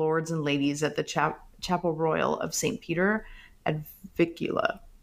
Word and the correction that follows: Vincula: Did I say that right, Carrie? Because I know you are the expert of all things English royalty. – I think it's Vincula. – All right, Vincula. There you go.